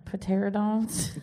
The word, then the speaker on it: Pterodons: The P is silent. – Pterodons? – Except for when Heather goes.